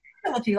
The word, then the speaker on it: Philippi: Hank Philippi Ryan, a pronunciation, is it Philippi or Philippi? – Philippi, like the Philippines. Philippi.